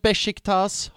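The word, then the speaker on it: Beşiktaş: För det är Besiktas, har jag lärt mig att uttalas.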